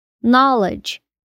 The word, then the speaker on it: knowledge: A letra D também fica silenciosa quando as letras D e G estão juntas, porque o som da pronúncia fica na verdade parecendo com o som da letra J, como em edge, borda, knowledge, conhecimento.